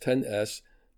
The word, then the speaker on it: XS: However, the new iPhone XS, or now pronounced XS, has the same starting price of $999, and it offers better specs overall.